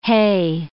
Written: hey

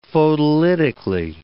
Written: photolytically

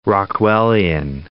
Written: Rockwellian

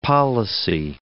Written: policy

